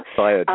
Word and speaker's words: Fayed: Fayed. Am I saying the name right? – Fayed, Al-Fayed, yeah, absolutely.